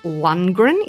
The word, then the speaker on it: Lundgren: So Sylvester Stallone wanted to make sure the boxing scenes looked so real in Rocky that he let Dolph Lundgren, is that how I say his name? – Yeah, Drago.